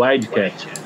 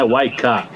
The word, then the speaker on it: Wildcat: Nós fomos lá conhecer a linha de produção do Wildcat, ou como os ingleses chamam, né, Wildcat, bem diferente da pronúncia que a gente utiliza aqui.